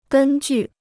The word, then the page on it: 根据: gēn jù